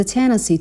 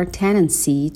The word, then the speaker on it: tendency: The other problem is a tendency toward perfectionism. Tendency or tenancy توی لهجه آمریکایی به این شکل هم تلفظ میکنن.